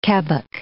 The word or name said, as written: kebbock